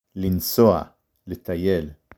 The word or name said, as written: לנסוע